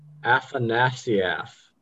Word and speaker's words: Afanasieff: I was gonna say she co-produced with Walter Afanasieff. – Yep, that's definitely how you say it.